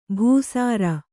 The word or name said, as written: ಭೂಸಾರ